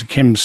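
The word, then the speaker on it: d'Yquem: Small d, right. Apostrophe, capital Y Q U E M, pronounced a Chem, the D is silent.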